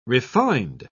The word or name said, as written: refined